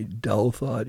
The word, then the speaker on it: Douthat: And the person who brought that out most clearly in the public realm was the New York Times columnist, op-ed writer, Ross Douthat, D-O-U-T-H-A, Douthat, you pronounce it.